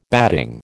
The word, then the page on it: batting: American English